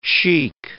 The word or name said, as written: sheik